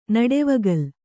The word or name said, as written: ನಡೆವಗಲ್